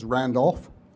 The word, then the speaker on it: Randolph: I go by the name of Randy Schiller, but my legal name is Randolph Schiller, R-A-N-D-O-L-P-H-S-C-H-I-L-L-E-R.